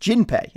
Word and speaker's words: Jinpei: You are correct in that Keop was just a kid named Junpei, pronounced Jinpei in the original.